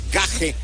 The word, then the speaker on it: Gage: Gage, se escribe Gage, G-A-G-E.